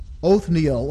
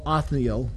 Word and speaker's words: Othniel: We'll look quickly at Othniel or Othniel. Again, it's one of those names that is pronounced two different ways.